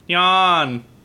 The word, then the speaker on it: Nyan: Do you really pronounce it Nyan? – Nyan. Nyan. – Nyan Cat. It says it in the song.